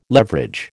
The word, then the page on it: leverage: American English